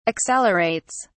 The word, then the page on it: accelerates: British English